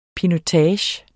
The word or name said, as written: pinotage